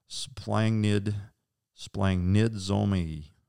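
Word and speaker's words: splanchnizomai: So the self pronunciation is splangnid zomai. It's spelled splangnizomai. Praise the Lord.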